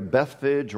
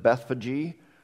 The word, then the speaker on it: Bethphage: So they came down this hill on over here to Bethphage, or some pronounce it Bethphage, on over to Bethany.